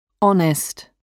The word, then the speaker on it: honest: Le H est muet, c'est-à-dire qu'il ne se prononce pas. – Hour. Air. Honest.